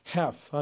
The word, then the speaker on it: half: 그리고 HALF도 half 하는 게 미국식이라면 half 하는 것이 역시 영국식이 되겠습니다.